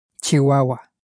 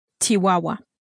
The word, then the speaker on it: Chihuahua: The city or province Chihuahua is, of course, in Spanish pronounced Chihuahua. – Chihuahua.